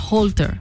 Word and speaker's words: Holter: Me están diciendo que el examen que yo me quiero hacer se llama Holter. Así se pronuncia. Se escribe Holter con H, O, o sea, H-O-L-T-E-R.